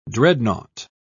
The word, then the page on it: dreadnaught: British English